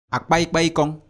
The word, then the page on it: akpaikpekong: Efik